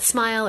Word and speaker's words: SMIL: But to be fair, right now, there's some things that smile or smell, depending on how you like to pronounce it, can do that other things can't.